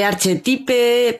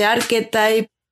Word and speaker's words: Archetype: O sea, se escribe con CH en vez de Q, eh, tiene una Y después de la T, eh, y bueno, se lee archetipe, pero se dice archetype.